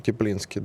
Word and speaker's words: Теплинский: Я еще и почему об этом вспомнил вчера, насколько, да, 9 января свой день рождения отметил Михаил, Ю, Михаил Юрьевич Теплинский. Это командующий группировкой Теплинский. войск. – Теплинский, да, правильное ударение, действительно.